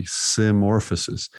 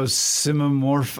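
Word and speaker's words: symmorphosis: I always go simomorph. Like I stumble it. – I add a syllable.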